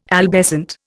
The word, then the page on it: albescent: Indian